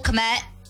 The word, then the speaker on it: Kmet: There's going to be appearances from the Bears coach, Ben Johnson, and players Cole Kmet. Did I say that right? – Yes, you did.